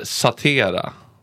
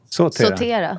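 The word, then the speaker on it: sautera: Vad betyder satera? – Sotera.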